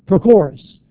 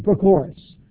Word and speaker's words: Prochorus: The third person there is a person, and I don't know that everyone here could say it, Prochorus is how you say it. That's his name, Prochorus.